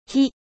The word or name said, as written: feu